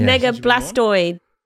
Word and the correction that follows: Blastoise: Actually, I said it wrong. It's Blastoise, is it? – Yeah, I don't Pokemon.